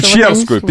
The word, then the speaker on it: Печерскую: Еще Печерскую, вот Печерскую, черт, я ошибся с ударением.